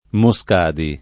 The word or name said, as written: Moscadi